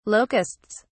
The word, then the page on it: locusts: British English